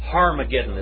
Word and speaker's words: Harmageddon: The word Armageddon. Harmageddon is how you say it, actually.